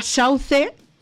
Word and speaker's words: Sauze: Lo digo bien, Lágrimas, de la banda Sauce, con la colaboración de Víctor García de Warcry. ¿Puede ser? ¿Lo digo bien? Sí.